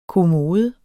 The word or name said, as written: kommode